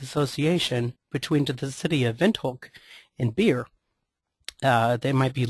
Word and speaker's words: Windhoek: In examples of these, the mark is actually pronounced Windhoek.